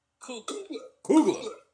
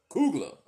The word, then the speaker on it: Coogler: Ryan Coogler? – Coogler. – Coogler.